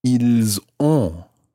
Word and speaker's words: ils ont: And that becomes ils ont. – Ils ont. – And you pronounce the S of il, I-L-S, because it's followed by a vowel, in this case, O-N-T. Ils ont.